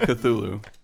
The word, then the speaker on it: Cthulhu: It's Cthulhu, dude. – Chalupa? – Chalupa? – Chalupa? – Chimichanga? We can't understand you. I've only ever seen the word written. What? – It's Cthulhu.